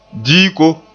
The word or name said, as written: diiko